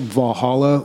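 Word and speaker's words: VA-11 HALL-A: This game is called Valhalla, but it's spelled with ones and, VA-11 space HALL-A.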